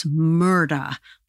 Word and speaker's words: Murdaugh: If you pronounce Alec Murdaugh's name correctly, as they do down there, instead of Alex, it's Alec. And instead of Murdaugh, it's Murda, like murder, but no R.